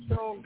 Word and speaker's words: Schoen: and that is Joe Sean from Buffalo, however you want to pronounce his name, Schoen, S-C-H-O-E-N. – Yes, and Sean, you got it right, baby.